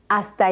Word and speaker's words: hasta: Hasta el final. – Remember that the H is never pronounced in Spanish.